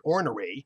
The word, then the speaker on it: ornery: I had a North Carolinian say we pronounce it ornery,